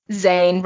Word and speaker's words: Zane: I was like, Zane rhymes with sane, which is something he's not.